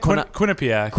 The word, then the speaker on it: Quinnipiac: Quinnipiac.